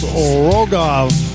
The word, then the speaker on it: Rogov: I believe it's pronounced Rogov, R-O-G-O-V, Rogov.